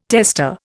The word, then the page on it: testa: Indian